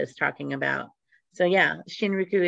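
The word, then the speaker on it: Shinrin-yoku: So Shinriku, did I say it right? – You're right. Shinriku.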